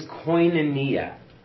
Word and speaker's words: koinonia: So the word is koinonia in Greek. I got the pronunciation on that one. Nailing it today. Koinonia. It's K-O-I-N-A-N-I-A. Koinonia.